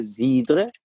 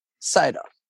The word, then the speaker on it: cidre: Oder na, die Franzosen sagen Cidre und die UK-Guys sagen Cider.